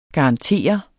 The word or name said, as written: garantere